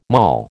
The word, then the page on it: moll: American English